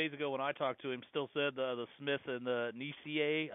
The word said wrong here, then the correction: Niasse: I'm not sure how he pronounces his name, the young man. – Niasse, there you go.